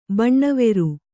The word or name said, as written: ಬಣ್ಣವೇರು